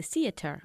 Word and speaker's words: theater: We can go to the theater, sacando la lengua para pronunciar bien la palabra theater. – Theater. – Eso es. Cuando digo sacar la lengua, quiero decir entre los dientes, ¿vale? – Okay.